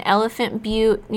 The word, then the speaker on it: Elephant Butte: He's now living in Elephant Butte, New Mexico. I know we pronounced that right because we've also done research and we've watched videos and documentaries and everything.